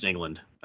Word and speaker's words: Englund: And you've got freaking Freddy Krueger himself, Robert Englund, or Eglund, and Edlund? Not sure. – I think it's just England. I think it's just pronounced England.